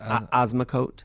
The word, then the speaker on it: Osmocote: I used Osmotico. How do you say that? – Osmocote. – Osmocote 2 months ago.